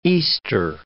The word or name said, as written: Easter